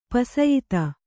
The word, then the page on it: ಪುಸಿಯೋತ: pusiyōta